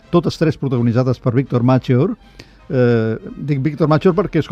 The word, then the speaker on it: Mature: Marcello Maturi, però ell va pensar, Maturi, doncs ho canviaré per la E, i aleshores li pronunciaven Machor.